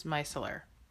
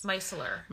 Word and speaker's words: micellar: It is micellar. – You think? – I'm positive it is. – Micellar. – Micellar. Or micellar. No, just micellar. – Okay. – Yes, micellar water.